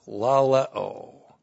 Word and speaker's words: laleo: Laleo. L-A-L-E, long O.